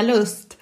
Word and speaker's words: Lust: I mean, I'm Swedish. I was born in Stockholm, and I would say Erika Lust.